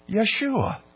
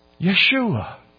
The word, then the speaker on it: Yeshua: By the way, do you know what the Hebrew pronunciation of Joshua is? You do. Yeshua. Yeshua.